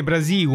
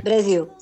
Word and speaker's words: Brasil: Ho detto bene Brasiu, Mafalda. – sì, eh. Brasil. – Brasil. Brasil.